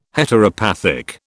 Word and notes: Heteropathic: British English

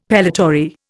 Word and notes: pellitory: Indian

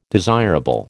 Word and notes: desirable: American English

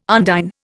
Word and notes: undine: Indian

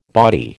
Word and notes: bawdy: American English

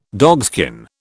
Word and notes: dogskin: British English

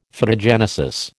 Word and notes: Phytogenesis: American English

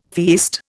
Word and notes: feast: Indian

